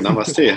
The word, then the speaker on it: Namaste: Sehr gut, Namaste.